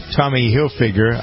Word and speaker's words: Tommy Hilfiger: These two fellas, Drew Taggart and Alex Paul, have teamed up with Tommy Hilfiger. I never pronounced that right. I think. Do I? – You just did.